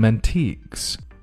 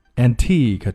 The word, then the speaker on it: antique: 第二句，It's quite black and white.And we have some antiques. – Antique, 注意一定要发得非常轻要快 Antique, antique, 不要拖啊不要读成 antique, 那样不好听 Antique.